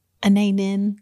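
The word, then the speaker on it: Anaïs Nin: Is it Ananin? – Yes. – Am I saying that right? – Yes, you are.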